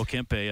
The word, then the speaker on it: Kempe: Oh, Kempe, yes.